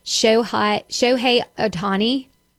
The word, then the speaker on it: Shohei Ohtani: John Smoltz, Justin Verlander, Jacob deGrom, Shohei Ohtani.